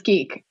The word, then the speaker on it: GIQ: The first is, and I think I shared this with you, was reading a report on virtual brand environments from, I don't know if they're pronounced G-I-Q or Geek. – It's Geek.